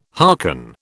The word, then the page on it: hearken: British English